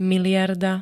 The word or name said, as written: miliarda